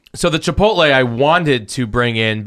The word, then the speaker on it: chipotle: The chipotle flavored one. – Chipotle.